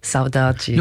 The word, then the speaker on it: Saudade: Morrendo de Saudade. – Saudade. – Your Brazilian pronunciations are brilliant.